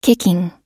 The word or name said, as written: kicking